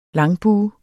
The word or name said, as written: langbue